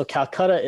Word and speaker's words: Calcutta: So Calcutta is the pronunciation that came into place after the British conquered India. – Okay. – And Kolkata is the native pronunciation of that word. They can be used interchangeably.